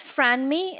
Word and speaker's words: Franmi: My name is Franmi. It's spelled F as in Frank, R-A-N-M-I.